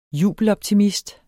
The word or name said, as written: jubeloptimist